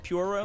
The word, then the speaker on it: Pyoro: Pyoro, is that how you pronounce it? – Puro. – Yep.